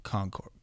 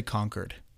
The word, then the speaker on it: Concord: Concord. I got a couple DMs that I said that wrong, so I'm going to say Concord. Concord, California, instead of Concord.